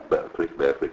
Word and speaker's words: Berkeley: si scrive L-O-C-K-E, Locke che è uno dei... Nel terzo si chiama Berkeley come la cittadina americana,